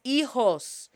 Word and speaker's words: hijos: For those that have children, estoy agradecido por mis hijos. H is shh. La H is silenciosa. Shh. H is silent. Hijos. And J is pronounced like an H. So la H is silenciosa. Shh. And J is pronounced like an H. H-I-J-O-S.